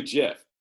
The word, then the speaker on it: GIF: You'll find the guy who invented the GIF says it's JIF.